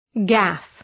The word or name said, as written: gaffe